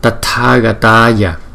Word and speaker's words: Tathagataya: Chữ T và chữ H chúng ta đọc là T, T, T. Chữ tờ nhưng mà chúng ta đưa cái hơi nhiều ra thành là T chứ không phải là thờ. T, T. Tathagataya, Tathagataya. Arhate, Arhate.